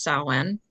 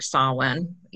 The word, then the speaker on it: Samhain: It's spelled different than it sounds. It's Samhain, but that's Gaelic or Old Gaelic, and so it's actually pronounced Samhain. Some people pronounce it like Samhain, either way.